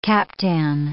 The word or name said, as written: captan